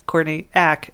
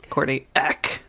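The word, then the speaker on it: Courtney Act: Because it's not Courtney Ack. It's Courtney Ack. – Ack. A hard one syllable.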